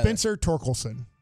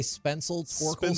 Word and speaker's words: Spencer Torkelson: Spencer Torkelson. – You did it. – That's the first time I've ever heard you say it right. Usually you say Spencer Torkelson.